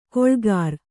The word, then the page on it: ಗಳೆಕಾರ: gaḷekāra